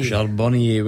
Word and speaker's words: Charbonnier: And one there that's, I couldn't quite get the again like pronunciation, but eventually I've got it. It's Lionel Charbonnier. – Charbonnier, well done, which means you've got one Celtic and two Rangers to get before the end.